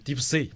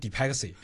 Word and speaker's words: DeepSea: 我们整个公司其实核心就两大产品，第一个是企业大模型 DeepSea，有人把它读成 DeepXea 都可以。